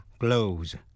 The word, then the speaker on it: clothes: Còn danh từ số nhiều, close, C-L-O-T-H-E-S, có thể đọc là close.